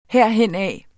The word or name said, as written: herhenad